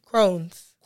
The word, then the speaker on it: Crohn's: It's pronounced Crohn's, right? – Crohn's. – Crohn's disease. There you go.